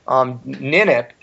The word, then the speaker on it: Ninip: Ninip, and that's the correct pronunciation, is an Akkadian god king warrior priest from a civilization dating back to the time of Ur in Sumer.